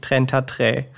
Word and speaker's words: Trentatré: Und 33 Trentatré.